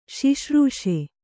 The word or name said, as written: ಶುಶ್ರೂಷೆ